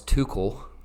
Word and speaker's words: Tuchel: And since Thomas Tuchel – that's actually how you pronounce it. Mm-hmm. I saw somewhere it's 2 cool.